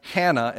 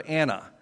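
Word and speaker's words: hannah (Ἅννα): So kind of interesting and I, I checked both of the major translations in, in, the greek and there's, there's actually the breath mark so you probably would pronounce it if you were reading the greek as hannah instead of anna but that's kind of a,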